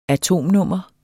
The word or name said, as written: atomnummer